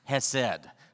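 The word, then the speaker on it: Hesed (חסד): The word, it's a Hebrew word, is hesed. It's not easy to say. You got to say it like you're hacking up something, in fact. Hesed. Hesed. Otherwise, they'll know you're not a native Hebrew speaker.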